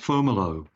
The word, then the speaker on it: Fomalhaut: It's about a disk of material around a star whose name is, it's usually pronounced Fomalo. It's spelled Fomalhaut, and it is in the southern hemisphere.